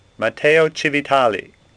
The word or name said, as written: Civitali